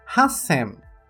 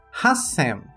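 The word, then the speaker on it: Hassem (八千): E para o número 8000, Hassem. Hassem, o qual mantém-se a pronúncia do Sam, mas o hachi é tirada a leitura ti e acrescentado ti saitsu, indicando a pausa.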